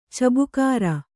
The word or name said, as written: ಚಬಕಾರ